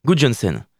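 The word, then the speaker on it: Guðjohnsen: Je vais vous parler d'un joueur islandais, Eyjurs Marie Est-ce que ça vous parle Gudjonsen, ouais. Gudjonsen. C'est la bonne prononciation.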